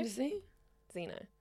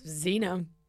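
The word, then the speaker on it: Xeno: It's X-E-N-O. Zeno? Zeno. – Yes, Zeno.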